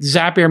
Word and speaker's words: Zapier: And I think I was the one who came up with Zapier makes you happier.